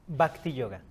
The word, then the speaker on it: Bhakti Yoga: conocido como, ¿cómo se pronuncia? Bhakti Yoga. – Bhakti Yoga.